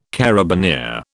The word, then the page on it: carabineer: British English